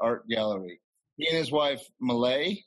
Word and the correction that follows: Malia: is that how you say it? – Malia. – Malia.